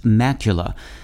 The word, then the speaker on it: macula: macula, pronounced in English as macula,